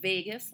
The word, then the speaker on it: vagus: The vagus nerve, like, like Vegas, like yes. the place? Well, okay. it's spelled V A G U S. – Okay. – But yes, pronounced like Vegas.